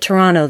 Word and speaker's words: Toronto: Apparently they say it Toronto there. So yeah, they don't pronounce the second T.